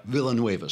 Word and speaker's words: Villanueva: One of them comes out, Alejandro Villanueva, who is one of, is that how you pronounce it? Villanueva? – Villanueva, sorry.